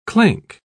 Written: clank

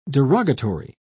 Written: derogatory

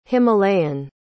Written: himalayan

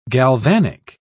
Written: galvanic

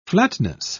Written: flatness

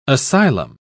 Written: asylum

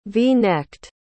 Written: v-necked

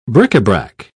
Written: bric-a-brac

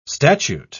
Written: statute

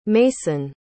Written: maisyn

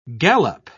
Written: gallop

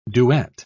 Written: duet

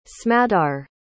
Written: smadar

